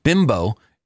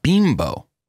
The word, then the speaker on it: Bimbo: He said, just so you know, Bimbo, as in the food brand that owns Entenmann's, is pronounced Bimbo. – Oh, yeah. – I always thought it was pronounced Bimbo, but in the past, they've run commercials on TV here in Connecticut, and in those commercials, I learned how to pronounce the name, Bimbo.